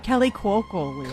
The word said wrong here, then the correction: colloquialism: Yeah, I need Greg just to say it correctly so I can repeat it. – Colloquialism. – Got it. Nailed it.